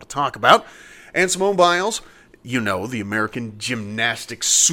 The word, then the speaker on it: Biles: Biles.